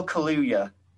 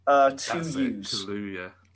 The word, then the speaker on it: Kaluuya: Daniel Kaluuya. – Kaluuya.